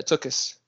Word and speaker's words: tuckus: And the word tuckus, is that how you pronounce it? – Probably. Yeah. Tuckus.